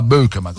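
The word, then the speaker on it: Beukema: Rimarrà con 4 centrali, rimarrà con Rachmani, rimarrà con Rafa Marin, con Marianucci, con Bökema, Sam Bökema, così si pronuncia, l'ha detto anche lui ieri sera, Sam, non Sam, Sam Bökema, questa è la vera pronuncia.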